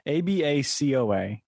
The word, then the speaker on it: Abacoa: And I don't know how to pronounce the actual little section you were in. See, I typed in Abacoa. Okay, that's an. Sorry. Shut up. Abacoa, Florida? Abacoa. ABACOA. Okay, this lady's a real estate agent. Wolf of real estate. Wolfie of real estate? Welcome to Abacoa. I said it right. Abacoa. Abacoa was right. I said it right. I've been saying it right the whole time.